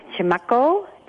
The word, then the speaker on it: Chimako: and help me pronounce it, Chimako? – It's Chimako. Chimako. It's a Pima word.